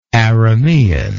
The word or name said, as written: Aramean